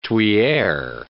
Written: tuyere

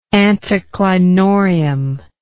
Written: anticlinorium

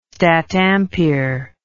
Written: statampere

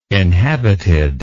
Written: inhabited

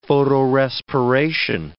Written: photorespiration

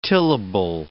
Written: tillable

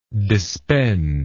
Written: dispend

